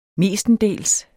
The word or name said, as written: mestendels